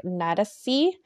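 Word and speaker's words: Nádasdy: She became engaged to Count Ferenc, and his last name is pronounced kind of weird, so it's like Nadasi.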